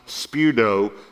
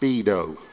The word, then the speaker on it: speudo: It's pronounced speudo. I wanted you to know that there is no Greek word pronounced speedo.